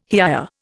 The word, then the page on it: hiya: Indian